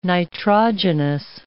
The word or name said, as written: nitrogenous